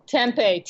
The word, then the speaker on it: tempeh: Tempeh, T-E-M-P-E-H. People like to pronounce it in different ways, but it's tempeh.